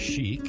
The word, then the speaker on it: Xiik: She has a new album out that is spelled X-I-I-K. In the Yucatan, that is pronounced chic.